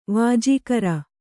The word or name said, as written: ವಾಜೀಕರ